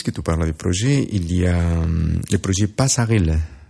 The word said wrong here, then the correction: Passerelle: Passerelle, on ne le prononce pas comme ça? – Oui, on a plusieurs projets en fait, hein, actuels, oui. Passerelle.